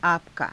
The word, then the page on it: aapa_ka: Hindi